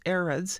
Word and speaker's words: AREDS: It's pronounced ARIDS, and it's spelled A-R-E-D-S, all capital letters, A-R-E-D-S.